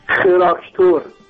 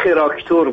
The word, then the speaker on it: خراکتور: خراکتور. – اسم نمایش اینه؟ – اسم نمایش کوپ یا خراکتور بوده. حالا من خراکتور بوده دیگه، تراکتور نبوده که.